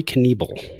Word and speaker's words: Knebel: How do you pronounce this guy's name? Knievel? Uh yeah, Corey Knievel. Knievel.